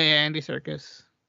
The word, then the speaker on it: Serkis: Yeah, Andy Serkis.